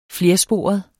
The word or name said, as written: flersporet